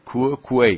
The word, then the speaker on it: quoque: خب تو کوه کوی، این لاتینه تو کوه کوی، این تلفظش هم خیلی مشکله، کوه کوی Q-U-O-Q-U-E کوه کوه تلفظ